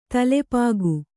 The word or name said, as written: ತಲೆಪಾಗು